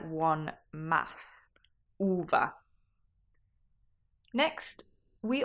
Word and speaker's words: uva: Uva. – So that's two syllables.